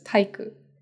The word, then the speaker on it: 体育館: Taiikukan. I don't know why we're going in depth with the pronunciation, but Taiikukan is spelled out with Taiiku, but it's pronounced usually just Taiku.